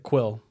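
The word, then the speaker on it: Kuiil: Quill or Krill, something like that. – It's K-U-I-I-L. – I think they pronounce it Quill. – Quill, okay, cool.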